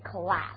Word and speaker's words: class: Okay, class. 这是比较 typical 的 American pronunciation. 发音的时候呢发的是 a, 所以非常低的那个音，不是我们英音当中的 class.